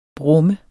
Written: brummer

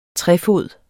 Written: trefod

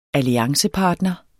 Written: alliancepartner

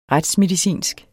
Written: retsmedicinsk

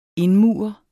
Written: indmure

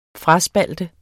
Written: FRASPALTER

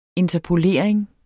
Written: interpolering

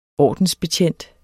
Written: ordensbetjent

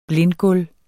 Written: blindgulv

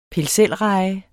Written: pil

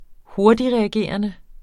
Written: hurtigtreagerende

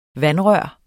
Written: vandrør

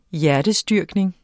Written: HJERTESTYRKNINGERNES

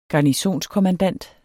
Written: garnisonskommandant

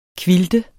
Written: quilte

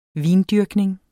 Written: vindyrkning

